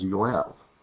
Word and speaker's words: Jael: Her name, when it's pronounced in Hebrew, is Yoel, which is just like the word, the name Joel.